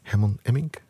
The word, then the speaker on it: Herman Emmink: Destijds groot geworden door Herman Emmink, zeg ik het goed? Jawel. Technicus Erik knikt goedkeurend, klopt.